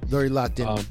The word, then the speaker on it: Lori Loughlin: And so basically it got broke when Felicity Huffman and Lori Loughlin, I think that's how you say her name. – Lori Loughlin.